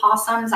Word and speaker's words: opossum: We have It looks like opossum, but it's pronounced possum. And I'm like, why just remove the O?